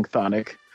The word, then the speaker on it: Chthonic: For those who don't know, although we're saying Chthonic, there's a couple of consonants before that TH sound that are not being pronounced.